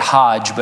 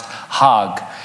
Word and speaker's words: Hajj: The annual pilgrimage to Mecca is spelled H-A-J-J. I would say it Hajj, but it's pronounced Hag.